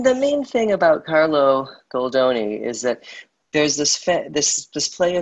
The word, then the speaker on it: Carlo Goldoni: His name was Carlos Goldoni. Am I pronouncing that right? – Carlo Goldoni, yeah.